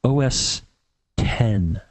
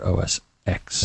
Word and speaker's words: OS X: I believe if you want to look like one of the cool kids, you want to say OS 10 and not OS X.